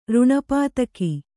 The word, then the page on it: ರುಣಪಾತಕ: ruṇa pātaka